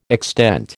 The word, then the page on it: extent: American English